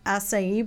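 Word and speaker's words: açaí: Acai bowl? – That was right.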